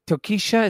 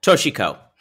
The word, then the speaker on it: Toshiko: And I'm like, and that's where I leaned in and I went, because she says his mother, Tokisha, is that how you say her name? – No, that was terrible. Toshiko.